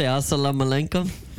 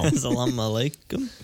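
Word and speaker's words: Assalamu alaikum: You see him say Asalam Malenkum? – He said it wrong, I think. – Why is I'm saying it wrong right now. – Asalam Alaikum.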